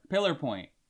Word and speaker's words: Pillar Point: Why do you think people should vote for Pilar points? – Why do you say it like that? – Pilar. – It's what? – Pillar. – Am I saying it? – It's pillar. Pillar point.